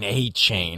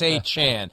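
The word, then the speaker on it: Achane: When I first heard the pronunciation of Devon A-Chain in the preseason, I could hear Chris Berman saying, Devon, take the A-Train, A-Chain. Well, now it's A-Chan, so it's take the A-Chan.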